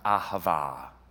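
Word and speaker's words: Ahava (אהבה): 2 weeks ago, I shared this word pronounced Ahava.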